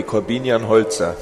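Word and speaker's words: Korbinian Holzer: So, in German, how would you say it? – In German, would be Corbinian Holzer.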